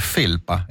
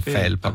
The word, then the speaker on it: felpa: Una delle cose meravigliose di Valentina è che, avendo sciacquato i panni in Arno, parla l'italiano con la pronuncia toscana corretta, quella standard, e dice felpa e non felpa, come diciamo noi altri.